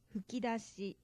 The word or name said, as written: fukidashi